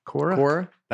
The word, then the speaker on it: Quora: How do you say that? – Quiro. – Q-U-A-R. – Quora? – Quora. – That's how I would say it.